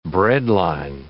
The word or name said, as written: breadline